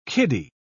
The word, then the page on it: kiddy: British English